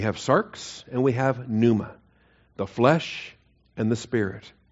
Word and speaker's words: pneuma: Pneuma, P-N-E-U-M-A, pneuma, silent P on the pneuma.